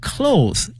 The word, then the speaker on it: cloth: 如果把衣服后面这个 es 去掉的话呢，它的意思呢也发生了变化，同时呢发音也随之呢发生变化，从原来的这个 clothes 衣服，那变成了 cloth，c-l-o-t-h。把 es 去掉，同时呢发音从原来的 o 变成了 o 音啊，希望同学们一定要注意了。